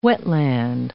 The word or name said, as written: wetland